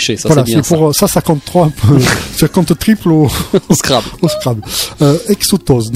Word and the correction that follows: exostose: donc ça s'écrit exostose, ça s'écrit E-X-O-S-T-O-S-O, calcanéenne, donc voilà.